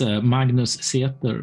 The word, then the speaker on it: Magnus Seter: Well, it's Magnus Seter, but English-speaking people can't pronounce my name, so that's okay. English, it's close enough.